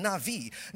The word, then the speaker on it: Navi: Same thing in Hebrew, but the only difference is in Hebrew, sometimes the B can be pronounced as a V, so it's called Navi.